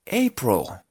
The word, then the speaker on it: April: Entonces, este es un muy buen ejemplo porque esta palabra se pronuncia April. Sí, aquí la I suena como una O.